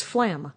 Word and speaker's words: Flam: I always thought it was Flan. And then I thought, is it pronounced Flan? But it isn't because it's Flam. – It's Flam. – With an M.